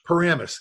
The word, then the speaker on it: Paramus: Paramus. – Paramus.